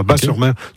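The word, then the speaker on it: Batz-sur-Mer: Oui, Bat, moi, on prononce Bas, le T ne se prononce pas, Bas-sur-Mer.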